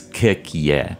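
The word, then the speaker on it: koekje: I'm going to pronounce it all wrong, but kukje. – I think it would, and I should say, because Dutch is pronounced in a way that I don't understand, but as best as I know, it's kukje.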